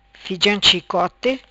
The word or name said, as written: Fijam Chicote